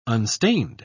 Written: unstained

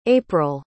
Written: apryll

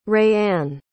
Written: raeanne

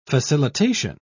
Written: facilitation

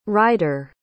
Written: ryder

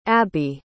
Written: abi

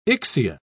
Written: Ixia

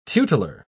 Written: tutelar

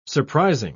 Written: surprising

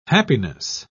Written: happiness

